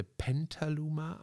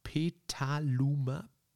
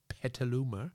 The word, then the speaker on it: Petaluma: Ich vertue mich immer mit, dem, mit der Aussprache des Namens. Ich dachte Pentaluma, aber es ist Petaluma. Petaluma.